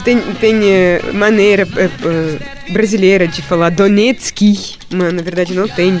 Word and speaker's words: Donetsk: Maria é da cidade de Donetsk, que eu devo ter pronunciado errado. – Não, você pronunciou muito certo, Donetsk.